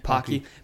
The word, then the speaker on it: Pocky: Pocky? – Yeah. – Pocky.